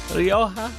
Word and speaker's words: Rioja: R-I-O-J-A is pronounced Rioja, Rioja.